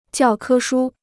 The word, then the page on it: 教科书: jiào kē shū